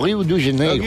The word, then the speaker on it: Rio de Janeiro: O Rio de Janeiro. – Rio de Janeiro, exactament.